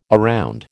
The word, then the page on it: around: American English